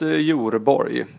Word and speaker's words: Jorborg: Julius Jorborg. Jag brukar alltid få bokstavera det. J-O-R-borg.